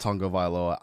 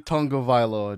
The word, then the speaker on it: Tagovailoa: It's actually pronounced Tagovailoa. I apologize. I was so incorrect. I've seen interviews where they say Tagovailoa, but it's actually Tungavailoa, just the way people have been saying it.